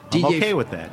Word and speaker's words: Didier: Didier Fornerol. – Spell it. D-I-D-I-E-R.